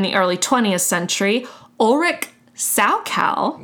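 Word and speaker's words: Salchow: It's spelled Saukal. That's why I said chow, but Saukal, yes.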